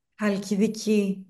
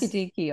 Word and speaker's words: Halkidiki: Is it Halkidiki? – Halkidiki, yes. – Halkidiki, okay. – It's Halkidiki.